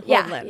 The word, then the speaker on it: Holdland: So people are like, how do we say this? Holdland. So Holdland. Think like Iceland. – Okay. Iceland. Holdland.